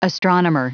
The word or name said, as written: astronomer